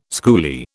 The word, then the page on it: schoolie: British English